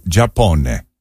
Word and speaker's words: Giappone: The Italian word for Japan is il Giappone. – Il Giappone. – And Giappone is spelled G-I-A-P-P-O-N-E.